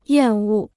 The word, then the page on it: 厌恶: yàn wù